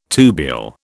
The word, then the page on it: tubule: British English